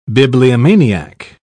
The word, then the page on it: bibliomaniac: British English